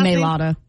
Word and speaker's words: Mailata: So It's Jason Kelce, it's Lane Johnson, and it's Jordan, how do you pronounce his last Mailata. – Name? Mailata.